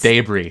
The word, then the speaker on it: debris: Love that that's how you say debris. It's debris. – Debris. – I think you put the wrong emphasis. – On the wrong syllable.